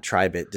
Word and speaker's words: Tribit: Because I subsequently found out from previous coverage after talking and saying it out loud in review that it is not Tribit, despite the way it's spelled. T-R-I-B-I-T. It's Tribit.